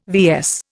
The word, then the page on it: VS: Indian